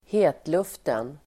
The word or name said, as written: hetluften